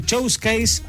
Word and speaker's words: showcase: showcase, así se pronuncia, ¿no? – Showcase. – Muy bien, está muy bien.